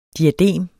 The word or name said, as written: DIADEMERNE